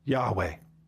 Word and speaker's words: Yahweh: In the Old Testament, when you see the word Lord all in capital letters, it stands for YHWH, which we pronounce Yahweh.